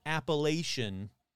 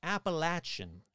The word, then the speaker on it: Appalachian: And in case you want to say, you're supposed to pronounce it Appalachian, no. I was told after the last one, it's Appalachian, Appalachian.